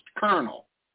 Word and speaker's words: colonel: First of all, colonel, it's not even right, because looking at the word colonel, there's no way that is pronounced colonel. I'm sorry. This is outrageous. – There's an R that doesn't even exist.